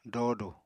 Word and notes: doodu: apatani

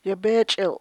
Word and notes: yebeechil': Chukchansi

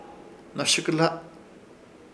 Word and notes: Hnoshɨkɨhla: Ishir Chamacoco

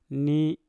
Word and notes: ni: Mpur

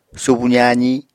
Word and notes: subu zaazi: Apatani

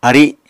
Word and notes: ari: Apatani

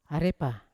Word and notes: arepa: Yawa

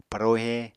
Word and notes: parohe: Apatani

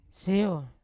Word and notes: seyo: Yawa

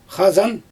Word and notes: xazan: Xyzyl

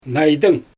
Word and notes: naydyng: Xyzyl

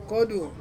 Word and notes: kodu: Ansus

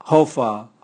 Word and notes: hovwa: Raga

